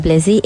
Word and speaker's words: Blezy: I am at Ada Blezy, A-D-A-B-L-E-Z-Y.